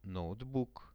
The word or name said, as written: notebook